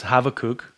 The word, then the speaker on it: Habakkuk: my mind has kept wandering back to the Song of Habakkuk, better pronounced Habakkuk.